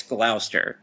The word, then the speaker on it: Gloucester: It might be spelled Gloucester, but it's Gloucester.